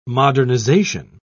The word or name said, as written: modernization